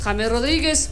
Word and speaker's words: James: Allá es James. Allá no se dice pues James, sino James. James Rodríguez, o sea, se pronuncia así.